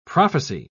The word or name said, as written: prophecy